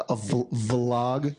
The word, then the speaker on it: vlog: That's with a V. I'm sorry, by the way. I should have pronounced it a little bit better, but instead of a blog, a vlog.